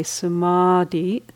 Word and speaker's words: samadhi: The D-H-I at the end of the word has the sense of holding. And the middle part of the word, the long A, samadhi, has the sense of a movement in it,